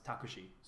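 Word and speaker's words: takushi (タクシー): Like a taxi cab would be written in katakana and it'd be pronounced takushi.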